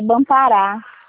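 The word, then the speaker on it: Banpará: É o Bampará, né? Eu não sei Era. – falar, pronunciar. – É Bampará.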